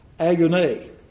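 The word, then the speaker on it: Agone (ἀγών): For I would have you know what conflict, and that word conflict, if you heard it, I'll just pronounce it for you in the Greek. Agone. What's that sound like? Agony.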